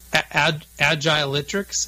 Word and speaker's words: Agiletrics: I know back in 2015, you published a model around company culture, and I think it's Agiletrics. Is that correct? Is that how you say it? – Yes, yes.